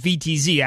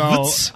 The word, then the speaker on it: Utz: My dumb ass thought it was VTZ as of like 3 years ago. So Utz. – I thought it was a V.